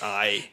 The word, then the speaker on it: arrête: Montréal, on dit arrête, parce que c'est un accent circonflexe sur le E. – Donc ça se prononce arrête.